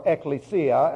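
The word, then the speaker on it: ekklesia: All right, the word that we're going to be studying, therefore, is the word ecclesia, or ecclesia, as it should be pronounced. That's E-K-K-L-E-S-I-A.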